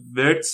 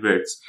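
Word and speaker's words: Wirtz: این آی ورتس تلفظ اسمش دیگه آره درسته آره. ورتس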